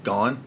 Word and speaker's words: Gane: Cyril Gane. – Gane? Is that how you say it? Yeah.